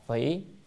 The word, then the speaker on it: phỉ: cái chữ này là đọc là phỉ,